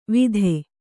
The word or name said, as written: ವಿತೆ